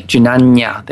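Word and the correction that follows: jñāna: Jnana, it's a long A. – Oh, it's a long A, not a tilde over the N.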